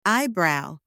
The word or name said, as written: eyebrow